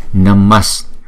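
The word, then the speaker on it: namaḥ: Thì chúng ta, cách chúng ta đọc câu chú này là Namas. Cho dù chúng ta viết chữ Namaha, nhưng mà chúng ta phải đọc là Namas.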